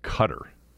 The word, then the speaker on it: Qatar: You know, the World Cup is in November in Qatar, which is, I think, the correct pronunciation of Q A T A R.